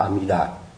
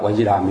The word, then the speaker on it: Amida: Thế nên mình đừng có chấp nhất tên, nhưng mà nếu mà mình muốn đọc cho nó đúng á, đúng cái âm chữ Phạn á, thì mình phải đọc là Amida. Còn nếu mà mình thấy người ta đọc Ajida mình đừng có bắt lỗi người ta, tại người ta quen thôi.